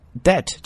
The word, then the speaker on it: debt: debt 这个词注意 B 不发音啊， debt 表示债务，那么 indebted 表示负债， heavily indebted 严重负债的武钢。